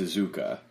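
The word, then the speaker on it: Iizuka: How do you say that? Izuka or Iska? Because I've heard it both ways. – I've heard it both ways, too, but I'm pretty sure the correct pronunciation is Izuka.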